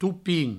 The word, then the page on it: topin: toupin